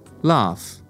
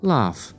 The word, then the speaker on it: laugh: Laugh. Laugh. And it sounds like the end of the word photograph. Laugh. Photograph. You write a gh, but you pronounce it as f.